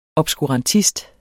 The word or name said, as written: obskurantist